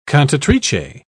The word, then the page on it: cantatrice: British English